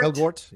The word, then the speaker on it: Elgort: Elgort, yeah.